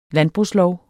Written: landbrugslov

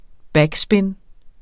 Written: BACKSPIN

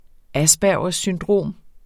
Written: syndrom

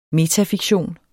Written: metafiktion

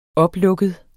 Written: oplukket

